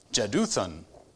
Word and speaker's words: Jeduthun: And that according to, I was not completely sure how to pronounce that. And at first I looked up one thing, and it's just an obscure enough of a name that it's not that easy to find pronunciation guide. And there's this one YouTube video I ran across. It was 34 seconds of just Jeduthun, Jeduthun, Jeduthun, Jeduthun.